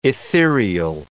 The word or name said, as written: ethereal